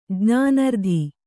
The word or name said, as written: ಜ್ಞಾನರ್ಧಿ